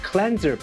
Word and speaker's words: cleanser: ครับเพราะ Cleanser แปลว่าผลิตภัณฑ์ทำความสะอาดผิวและต้องออกเสียงว่า Cleanser. ไม่ใช่ Cleanser.